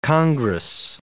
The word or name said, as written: congress